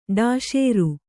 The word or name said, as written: ಡಾಶೇರು